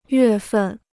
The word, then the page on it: 月份: yuè fèn